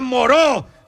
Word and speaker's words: Moreau: ¡Se pronuncia Moreau! ¡Doctor Moreau!